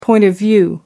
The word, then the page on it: point of view: American English